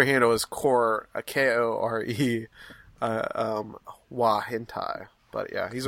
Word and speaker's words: hentai: Some of us can rattle off names for weird hentai movies. – Did I pronounce that right? Hentai? – It's perfect.